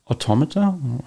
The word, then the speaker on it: automata: So I think that's like from automata theory, where you have some like automata. Is that the right pronunciation? – Yeah.